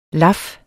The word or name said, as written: laf